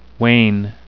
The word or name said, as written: wane